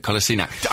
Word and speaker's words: Kolasinac: I think it's a hard C at the end of it. Kolasinac.